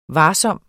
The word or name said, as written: varsom